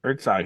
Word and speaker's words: Herzog: Herzog.